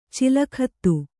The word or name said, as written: ಚಲಗತಿ